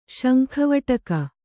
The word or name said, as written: ಶಂಖವಟಕ